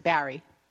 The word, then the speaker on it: Barre: There's no I E on it, so it's not Barry. It's right. Beer. – It's B A R R E, but they pronounce it Barry.